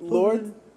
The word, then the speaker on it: Lorde: Lorde. – Wait, Lorde.